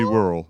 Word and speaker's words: Worrell: Worrell. – Worrell, okay, yeah. Worrell, all right. – Yeah. – Because it's spelled like W-O-R-R-E-L-L, and I'm just like, is it Worrell? – Yeah.